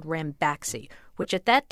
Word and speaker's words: Ranbaxy: Company called, is it pronounced Ranbaxy? – Yes, that's right.